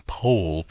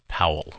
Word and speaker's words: Powell: I'm using the pronunciation Pohl because that's how David Case pronounces it. Most of us would pronounce it Powell, of course.